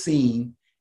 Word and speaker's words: Cine: And I had to figure out how this guy pronounced his name because it's spelled C-I-N-E. But his name is Lewis Seen.